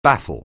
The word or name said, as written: baffle